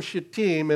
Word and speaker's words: Shittim: They moved from Shittim, and I prefer that pronunciation rather than putting it on the first syllable.